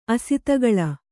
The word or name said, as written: ಅಸತಿಕೇಳಿ